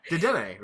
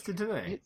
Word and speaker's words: Dedenne: Dedenne, right? That's how you say it. – Yeah, Dedenne. – It's Dedenne.